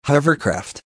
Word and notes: hovercraft: American English

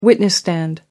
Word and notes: witness stand: British English

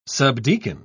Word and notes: subdeacon: British English

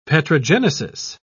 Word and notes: petrogenesis: British English

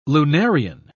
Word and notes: lunarian: British English

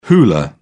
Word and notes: hula: British English

